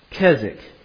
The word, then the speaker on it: Keswick: Keswick. You don't pronounce the W, it's just Keswick.